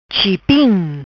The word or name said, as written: Chipim